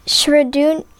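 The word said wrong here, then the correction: Schrödinger: Schrodinger's Schrodinger's cat.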